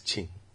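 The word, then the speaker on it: Qin: Yeah, China's foreign, new top diplomat, yeah, the foreign minister, King Gang, is that pronounced? – No. King Gang. – Chingang? It's Ching. Yeah.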